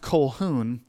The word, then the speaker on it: Colquhoun: Now, it looks funny. His last name is Colquhoun. But it's pronounced Colquhoun.